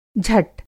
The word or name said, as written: झट